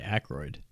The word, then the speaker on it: Aykroyd: I say Aykroyd.